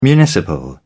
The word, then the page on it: municipal: British English